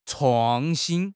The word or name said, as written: 创新